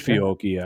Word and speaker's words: Fiocchi: Is that how you pronounce it? – I think it's Fiocchi actually, but, but yeah, that stuff.